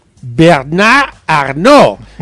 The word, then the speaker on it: Bernard Arnault: Número 3, ¿lo digo suave o lo digo bien francés? – A ver. – Bien francés. – Bernard Arnault.